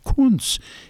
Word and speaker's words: Kuntz: She says, "How it's pronounced, K-U-N-T-Z. I says, I'm German extraction. My father spoke German. I says, that's pronounced Kunz.